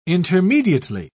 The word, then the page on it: intermediately: British English